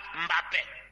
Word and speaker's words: Mbappé: Il a porté le drapeau de ses origines camerounaises, il faut toujours prononcer son nom en Douala, Mbappé.